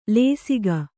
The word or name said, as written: ಲೇಸಿಗ